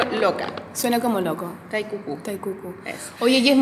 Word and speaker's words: QUQU: ¿Cómo se escribe? Con Q, U, Q, U. Cucu. Cucu. – Con Q de queso.